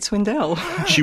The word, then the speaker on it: Swindells: Her maiden name was Swindells, and the name of the book was almost The Great Swindell. – She was born by the name of Ethel Swindell? – Ethel Swindells. – Yes. – Right. – Yes. – Stress on the second syllable.